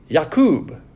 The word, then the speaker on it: Yaqub: D, Yaqub, which is how they pronounce it. We call it Jacob.